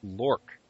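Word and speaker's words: L2Ork: This is L20RK, which is pronounced LORC.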